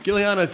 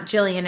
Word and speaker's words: Gillian: It was truly that my mom saw it in a book and for whatever reason thought it was Gillian and not Jillian, and obviously had never met anyone with the name to know it was Jillian, and so just named me Gillian.